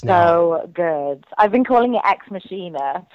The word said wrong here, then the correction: Ex Machina: I know. That's what I called it too. – And everyone's like, it's Ex Machina. And I'm like, oh, whatever. – Well, then spell it with a K.